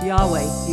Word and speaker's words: Yahweh: It is YHWH, pronounced Yahweh usually.